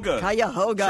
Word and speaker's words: Cuyahoga: Cuyahoga.